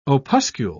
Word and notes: opuscule: British English

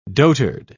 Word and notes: dotard: British English

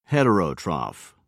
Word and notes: heterotroph: British English

